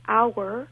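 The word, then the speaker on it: hour: If the H is silent, like our or herb, then we use an in front of it.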